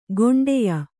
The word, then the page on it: ಖಂಡೆಯ: khaṇḍeya